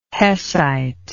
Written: hessite

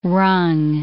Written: rung

